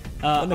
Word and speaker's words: Bundaberg: You have a drink of this awesome, how would you pronounce that? – Bundaberg? – Bundaberg? – Bundaberg. – Sure. Bundaberg, Australian home-owned.